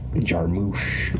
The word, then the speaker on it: Jarmusch: It's Jim Jar with a mushy ending. Jarmusch.